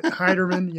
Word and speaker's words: Heiderman: I don't really care how you say it, but Heiderman, yeah.